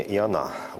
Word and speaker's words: Иона: Да, здесь все-таки с таким с еврейским вариантом произношения Иона, ударение на А.